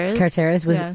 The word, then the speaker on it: Carteris: Do you know that Gabrielle Carteris, how do you say her name? – Carteris was almost 30 years old when she was.